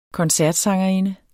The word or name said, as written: koncertsangerinde